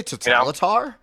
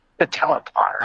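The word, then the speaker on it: Totalitär: It's like, you know, Catalatar, you know, esque, you know, just kind of hardcore. – Did you say Totalitar? – Is that how you say it? – Well, you know that band?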